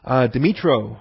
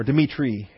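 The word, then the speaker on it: Dmytro: Dmitro, or Dmitri, actually, in the Ukrainian rather than Russian pronunciation.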